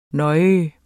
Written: nej

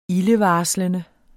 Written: ildevarslende

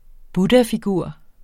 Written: buddhafigur

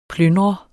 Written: plyndrer

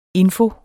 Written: info

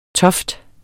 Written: toft

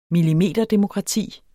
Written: millimeterdemokrati

